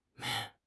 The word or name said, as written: Nian2